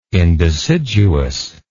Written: indeciduous

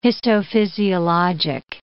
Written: histophysiologic

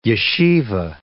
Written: yeshivah

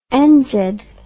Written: enzed

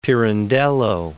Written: Pirandello

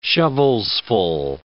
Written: shovelsful

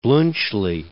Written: Bluntschli

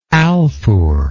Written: Alfur

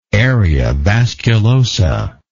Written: area vasculosa

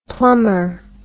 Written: plummer

